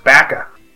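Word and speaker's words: Baka (バカ): Which, as I've recently learned, in Japanese is pronounced Baka.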